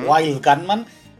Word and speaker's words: Wild Gunman: Por ejemplo, la del Will Goodman de Nintendo era Wild Gunman, bien pronunciado.